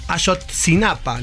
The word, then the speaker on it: Ayotzinapa: Ayotzinapa.